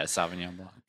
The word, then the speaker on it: Sauvignon Blanc: I usually like if I buy wine, I usually get I'm gonna butcher it, like a Sauvignon Blanc. Is that how you say it? – Yeah, Sauvignon Blanc.